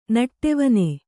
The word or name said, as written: ನಡುವನೆ